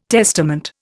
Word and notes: testament: Indian

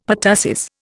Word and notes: pertussis: Indian

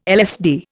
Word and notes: LSD: Indian

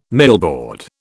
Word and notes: millboard: British English

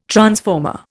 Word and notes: transformer: Indian

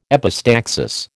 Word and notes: epistaxis: American English

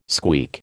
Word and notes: squeak: American English

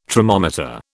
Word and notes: tromometer: British English

